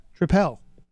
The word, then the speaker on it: Tripel: It's a fruited sour triple. Am I saying that right? Triple? – Yeah. – Tripel.